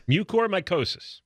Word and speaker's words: Mucormycosis: Mucormycosis. – Yeah, that's how it's pronounced? – Mucormycosis.